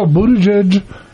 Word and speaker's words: Buttigieg: But when I look at the folks immediately behind him, Bernie Sanders, Pocahontas, Buttigieg, how do you say his name? – Yes, sir. – Buttigieg. – Nice job.